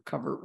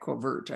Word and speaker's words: covert: covert war with France, or is that covert? I never could remember which way do you pronounce it. – Covert. – It is covert.